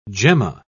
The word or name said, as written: gemma